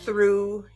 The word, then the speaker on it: through: Oh, through, you look so tough. Why? Because if you take away your TH, you get rough. But you are pronounced with a long U.